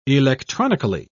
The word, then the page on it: electronically: British English